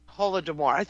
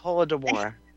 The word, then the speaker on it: Holodomor: And, you know, the Soviets actually starved them with a Holodomor? – The Holodomor. – Holodomor. Thank you. Holodomor.